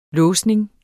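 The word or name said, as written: LÅSNING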